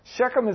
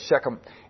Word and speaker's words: Shechem: And it's also called Sychem. Sometimes it's spelled Sychem. S-Y-C-H-E-M. But it's the same place, Shechem.